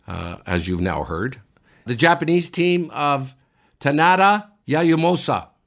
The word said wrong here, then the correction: Tanida: Kev, did I say that right? – Yasumasa Tanida. Yeah, that's the way you say it.